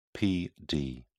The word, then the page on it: pd: British English